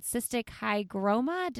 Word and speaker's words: cystic hygroma: You were born, I feel like I'm going to butcher this, but with a condition called cystic hygroma. Did I say it right? – Yeah, you said it right.